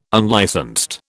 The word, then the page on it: unlicensed: British English